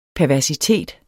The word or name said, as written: perversitet